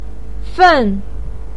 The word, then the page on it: fen: 4